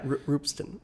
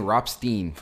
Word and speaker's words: Ropsten: Yeah, Roopsten. – Roopsten? Is that how you say it? – Yeah. – God, I was saying Ropstein for years.